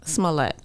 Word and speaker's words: Smollett: Is it Smollett or Smollett? – Smollett.